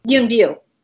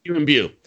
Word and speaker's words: Yuen Biao: Yuen Biao. – Yuen Biao.